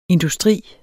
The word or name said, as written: industri